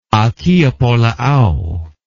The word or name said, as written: akiapolaau